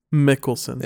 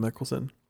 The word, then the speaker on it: Mickelson: Is it with an N or an M? – Phil. Wait. Phil P-H-M Mickelson. – Mickelson. – Yeah. – M Mickelson. – Yep. He's on the list.